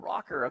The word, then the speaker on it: ROKR: And so he's rocker, okay, it's spelled R-O-K-R, that's a cool spelling.